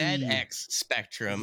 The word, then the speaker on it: ZX Spectrum: That's the ZX Spectrum. – ZX Spectrum. Okay. – That is you. a British computer, so you pronounce it ZX.